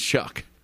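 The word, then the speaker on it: Tyler Shough: I think it's Shuck. – Shuck. Well, there's no K in there.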